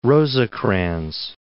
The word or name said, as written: Rosecrans